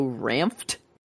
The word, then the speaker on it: Ranft: Vedder and Joe Ranft. I think is maybe how you say it. Ranft. R-A-N-F-T. But anyway, I was obsessed with Cars and Lightning McQueen.